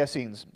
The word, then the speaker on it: Essenes: The Essenes, I'm not sure if I'm saying that right. Pardon me? The Essenes. There you go.